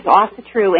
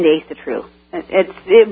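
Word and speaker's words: Ásatrú: Now, what were you before you were, is it an Asatruian, It's pronounced two ways, Asatru and Asatru. Both are correct or incorrect, whichever you want to say.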